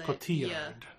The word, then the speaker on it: Cotillard: She's French, so you would pronounce it in the French way. – Yes. – I would pronounce it in the Spanish way, but that would be wrong. – I think in French you often put the syllable on the emphasis on the second syllable. – Okay. – I think the double L is a Cotillard.